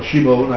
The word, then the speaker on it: Shimon: Simon is his original name, you know, that Jesus, he was called Simon, or Shimon, I think was probably how it would be pronounced.